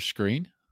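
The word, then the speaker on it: Skrine: They've been pronouncing it for the past couple of weeks as Buster Screen.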